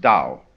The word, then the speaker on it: Tao: T-A-O, you would think it was pronounced Tao or Tao, but we've Romanized Chinese in such a way that only those in the know can decide how it's pronounced. We should have spelled it D-O-W, you see, but it's T-A-O.